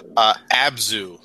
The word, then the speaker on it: Abzu: Abzu, am I pronouncing that right? – I believe so.